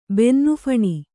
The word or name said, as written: ಬೆನ್ನುಫಣಿ